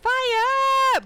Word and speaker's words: FIYE: Just tag it on Twitter with the hashtag FIYE bonus. That's pronounced fire bonus.